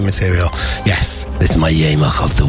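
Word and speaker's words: Jemig: It is my Yamach of the week. If you're a new listener, you're always wondering, what the hell is a Yamach? Or Jamig, as it's spelt in wonderful Dutch language.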